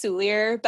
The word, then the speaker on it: Soulier: I say it the English way, which is Soulier, but I'm sure I'm butchering it because it's French.